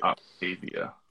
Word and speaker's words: Optavia: So forgive me if I slaughter the company name, but Optavia? It sounds right to me. You know how they say it like it's spelled? It's exactly what I did. – Optavia.